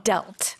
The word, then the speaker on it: dealt: Dealt. – Dealt. Mm-hmm. 주의하셔야 될 것 같아요. D-E-A-L-T에 T를 넣어주시고 발음도 dealt가 됩니다.